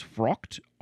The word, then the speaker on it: fraught: I believe it's frocked. Frocked. Okay, fascinating. That's not how I wanted to say the word. It is spelled F-R-A-U-G-H-T, frocked.